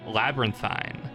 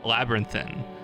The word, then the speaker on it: labyrinthine: But we, as, you know, uneducated Philistines, pronounced it Labyrinthine. We've been informed by a very helpful listener here today that you pronounce it much like serpentine, labyrinthine. Wait, no, I've misread that. Labyrinthine. Like serpentine? Yeah, that's right.